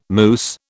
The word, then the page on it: mousse: British English